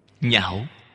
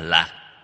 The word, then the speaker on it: nhạo: Nhạo, cái chữ này đọc trại âm, không đọc là lạc.